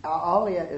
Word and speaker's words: alaya: So we have, but we have the first, the eighth consciousness is alaya, is spelled A-L-A-Y-A, like the Himalayas.